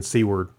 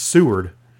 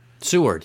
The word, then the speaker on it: Seward: We flew into Vancouver and took the cruise along the coastline, went to Ketchikan, Juneau, Icy Point, and Skagway, and then Seward. Or, I'm sorry, I keep saying it wrong. It's Seward. – Seward, yes.